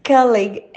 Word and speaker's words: colleague: Colleague has unusual spelling because it is borrowed from French. So when people see it written down, they often assume it has a soft G sound, like the words village or privilege, but it doesn't. It is colleague, colleague.